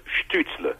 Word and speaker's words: Stützle: And the U with little dots on it, tréma, as we say in French, becomes U, not U. So okay. – In my show, he was Stützle.